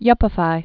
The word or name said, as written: yuppified